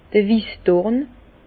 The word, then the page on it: d'r Wissdorn: Bas Rhin; Herrlisheim